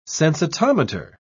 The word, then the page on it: sensitometer: British English